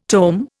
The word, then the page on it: tome: Indian